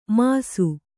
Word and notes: ಮಾಸು: māsu

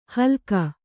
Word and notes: ಹಲ್ಕ: kannada

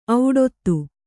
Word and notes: ಔಡೊತ್ತು: auḍottu

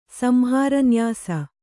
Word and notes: ಸಂಹಾರನ್ಯಾಸ: Kannada

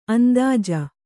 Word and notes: ಅಂದೋಜಿ: andōji

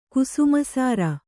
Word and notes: ಕುಸುಮಾಸಾರ: kusumāsāra

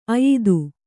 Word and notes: ಅಯಿದು: kannada

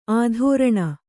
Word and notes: ಆಧೋರಣ: Kannada

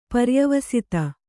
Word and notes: ಪರ್ಯವಸ್ಥೆ: paryavasthe

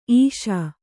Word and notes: ಇಸಿ: isi

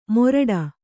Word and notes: ಮೋರಟ: kannada

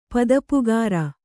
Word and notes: ಪದೆಪುಗಾರ: padepugāra

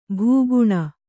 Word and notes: ಭಗಣ: bhagaṇa

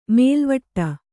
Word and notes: ಮೇಲ್ವಟ್ಟ: mēlvaṭṭa

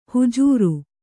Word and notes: ಹುಜುರು: Kannada

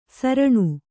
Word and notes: ಸಾರಣ: kannada

